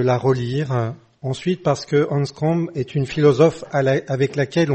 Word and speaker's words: Anscombe: Nous sommes réunis ce soir autour d'Elisabeth Anscombe. Alors je le prononce à la française, il faudrait peut-être dire Elisabeth Anscombe pour utiliser plus la prononciation anglophone.